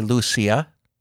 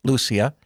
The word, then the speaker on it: Lucia: So our Blessed Mother came, and the children to whom she appeared, Lucia, or some say Lucia, the Portuguese put the accent on the second syllable, so or the first syllable rather, Lucia, and then Jacinta, and Francisco.